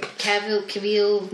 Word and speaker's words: Cavill: Cavill? Is that how you say it? – Cavill.